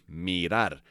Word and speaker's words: mirar: Mirar. And that is spelled M as in Mary, I-R-A-R. Mirar, to look or to watch, okay?